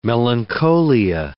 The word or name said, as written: melancholia